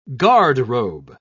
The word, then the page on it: garderobe: British English